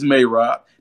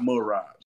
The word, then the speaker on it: Merab: when he come on the show, he's like, yeah, it's Mayrab. Then why the fuck you be fucking saying it wrong, too? Like, you got everybody, it's Mayrab, not Merab.